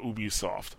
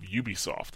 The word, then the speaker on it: Ubisoft: like they had a little PSA about the pronunciation of Ubisoft, and they said yes, the word comes from ubiquitous.